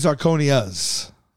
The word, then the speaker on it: zirconia: Well, I think it's zirconias. – Yeah, there's no M.